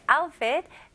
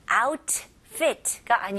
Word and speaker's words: outfit: 네, 여기는 중간에 T가 있잖아요. 영어에는 주로 이제 T가, 특히 미국식 영어는 중간에 T가 있으면 T가 그렇게 강하게 발음을 안 해요. 그래서 outfit가 아니고요. T를 아주 가볍게 outfit. 이렇게 그래서 혀가 그 입 천장을 닫자마자 바로 F로 가는 거예요.